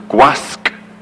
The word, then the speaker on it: Gwasg: You must pronounce the G on the end of Gwasg, otherwise you refer to the Gregynog slave or manservant. Gwas is the word for slave.